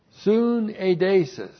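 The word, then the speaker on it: syneidesis: Now the word conscience is the Greek noun synaedesis. Looks like that in the Greek. That's S-U-N-E-I-D. Long E, S-I-S. Synaedesis.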